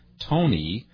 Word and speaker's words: Tony: Ruby is trying to get her friend Tony to go bird watching with her. Ruby is both a girl's name and the name of a 宝石 home 宝石 is also Ruby. Tony is always a boy's name when it is spelled this way, T-O-N-Y, but if it is spelled T-O-N-I, then it's a girl's name, but they are pronounced the same, 都是 Tony.